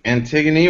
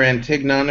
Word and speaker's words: Antigone: We're discussing Antigone, or Antignon if you pronounce it incorrectly, like I originally did.